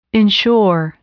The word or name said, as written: insure